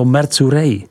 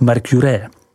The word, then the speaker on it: Mercurey: a to Mercurey, vyslov Mercurey.